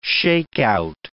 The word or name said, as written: shakeout